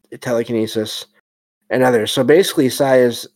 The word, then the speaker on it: psi: The letter P is silent. And the next one is psi, PSI.